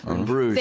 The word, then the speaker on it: Bruges: It's directed by that guy who did Bruges. Is that how you say it? – Yes. Bruges.